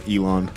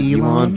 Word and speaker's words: Elan: Elon. – I say that's Elon. How I pronounce it, Elon. But I'm like coming to terms that I've probably never known how to pronounce my name.